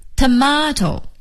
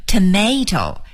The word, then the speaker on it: tomato: 好了，说完了干酪，咱们再来看看这个西红柿的说法 tomato, T-O-M-A-T-O, tomato, 西红柿 tomato, 美音的发音呢是念成 tomato, T-O-M-A-T-O, tomato, 所以 tomato 和 tomato 都是对的。